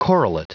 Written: correlate

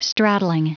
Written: straddling

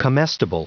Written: comestible

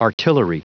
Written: artillery

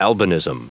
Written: albinism

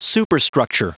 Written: superstructure

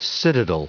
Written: citadel

